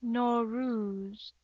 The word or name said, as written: Nowruz